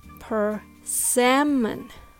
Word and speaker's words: persimmon: Do you think the stress is on the first or the second syllable? Well, the answer is persimmon.